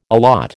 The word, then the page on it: allot: American English